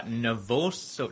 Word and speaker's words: nervosa: So, yeah, obviously you were talking there about anorexia nervosa.